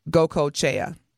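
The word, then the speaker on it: Goicoechea: Her mother is Italian, but she gets her last name from her Puerto Rican father, which is pronounced Gokochea. So Usher Raymond and Gokochea, and they've been dating since 2019.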